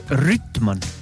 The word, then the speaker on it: Rüütman: Lähemalt teadandeid sai siis Rüütmanni käest Raplas. – Või oli see kuulus Rüütman? – Jah, kahe üüga kirjutatakse. Rüütman võis hääldus olla küll.